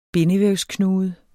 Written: bindevævsknude

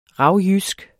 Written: ravjysk

